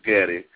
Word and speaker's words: Gaddie: Which is Kenneth Gaddy, and the last name is spelled G-A-D-D-I-E.